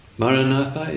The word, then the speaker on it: Maranatha: Maranatha. Four equally stressed syllables. Maranatha is the oldest prayer there is in the Christian tradition.